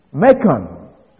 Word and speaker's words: Marcion: Macon, his name is spelled M-A-R-C-I-O-N.